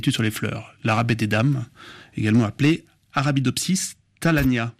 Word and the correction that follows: Arabidopsis thaliana: Alors, l'arabette des dames, effectivement, Arabidopsis thaliana. Je corrige le petit défaut de prononciation.